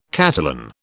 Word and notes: Catalan: British English

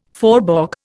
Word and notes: faubourg: Indian

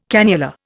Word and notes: cannula: Indian

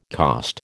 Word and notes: cost: American English

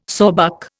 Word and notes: sawbuck: Indian

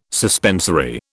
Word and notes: suspensory: British English

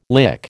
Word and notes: lick: American English

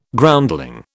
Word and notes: Groundling: British English

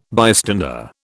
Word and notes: bystander: British English

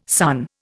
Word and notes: son: Indian

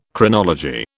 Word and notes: Chronology: British English

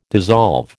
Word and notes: dissolve: American English

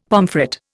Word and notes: pomfret: Indian

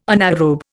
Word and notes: anaerobe: Indian

